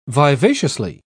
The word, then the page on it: vivaciously: British English